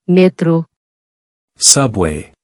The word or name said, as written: Metro